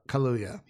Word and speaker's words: Kaluuya: And stars Daniel Coulier, Jody Turner Smith, and Coulier is from Full House. – Oh my God, you're right. Kaluuya. Yeah. Wow, yeah, that is right.